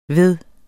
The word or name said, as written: ved